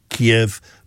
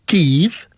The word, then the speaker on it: Kyiv: like Kyiv, for example, which people say Kiev, but now it's the Ukrainian pronunciation, so you must say Kyiv.